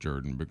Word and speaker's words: Jordan: he would say it's pronounced Jordan, like the river, as if the river.